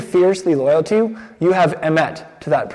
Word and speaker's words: emet: The Hebrew is emet. Can we all say emet? You could spell it E-M-E-T.